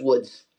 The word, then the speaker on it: Łódź: They lived in the city of Łódź, which is L-O-D-Z, so I'm going to pronounce it Lodz, even though it's Woods if you're Polish.